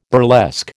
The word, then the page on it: burlesque: American English